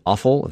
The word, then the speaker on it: Offill: You're recommending a book called Department of Speculation by Jane Offill. That's how you say her name, O-F-F-I-L-L.